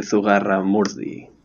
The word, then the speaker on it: Zugarramurdi: Would be Las Brujas de Zugarramurdi.